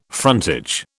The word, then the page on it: frontage: British English